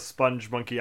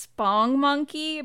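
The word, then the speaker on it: Spongmonkey: I mean, to be fair, I only recently became aware of what the sponge monkey actually was, thanks to Hannah. – I remember the Quiznos commercial. – I think it's actually pronounced spong monkey, but I like sponge monkey better.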